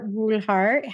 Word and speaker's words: Brulhart: Today, I have Kar Brulhart.